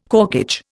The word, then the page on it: corkage: Indian